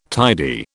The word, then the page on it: tidy: British English